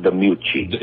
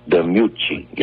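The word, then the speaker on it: DiMucci: Pronounce Dion's last name, the right Italian pronunciation, will you, please? – Dion DeMucci. – It's a mu sound, Mucci. – DeMucci.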